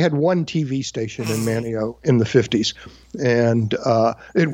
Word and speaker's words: Manteo: But one summer, I was 4 years old and had been watching Superman at home, and I'm visiting my cousin in Manteo, North Carolina. That's M-A-N-T-E-O. They don't pronounce the T. Manteo, North Carolina. And I said, hey, it's time for Superman.